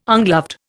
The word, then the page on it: ungloved: Indian